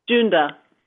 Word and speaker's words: Junda: So we have the next caller, and I see your name is J-U-N-D-A. I'm going to let you pronounce that for me. – Junda.